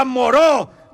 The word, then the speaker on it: Moreau: Doctor Murro, Carmen. – Se pronuncia Moreau, doctor Moreau.